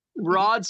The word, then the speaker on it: Rad: It's Rod. Rod spelled rad, rad Rod.